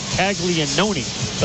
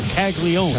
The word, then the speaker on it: Taglione: It's spelled C-A-G-L-I-A-N-O-N-E, like Taglianone, but it's just Taglione.